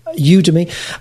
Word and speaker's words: Udemy: You've got what looks like a terrific new course on, am I pronouncing it right, Udemy? – Yes, yeah. – Udemy. Okay, and which highly recommend.